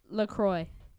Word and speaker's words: LaCroix: And I got the LaCroix. – LaCroix. – You know? – You don't I'm pronounce like the X, it's up, French. – The why is there an X then? – It's French.